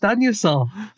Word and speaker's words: Anusol: I believe it's pronounced Anusol. – Yeah, is it? Heck, it's so clearly no, Anusol. – The company says it's pronounced Anusol.